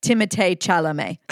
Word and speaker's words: Timothée Chalamet: And I pronounce that, to be clear, Timothée Chalamet.